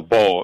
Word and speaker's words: Beau: No, Paul. B-E-A-U, Paul Williams.